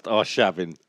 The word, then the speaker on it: Arshavin: Andre Arshaven. – I think it's pronounced Arshavin.